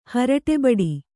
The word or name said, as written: ಹರಟೆಬಡಿ